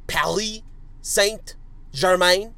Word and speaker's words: Paris Saint-Germain: You may remember my correct pronunciation of it, my French pronunciation of it. Paris Saint-Germain, PSG from France.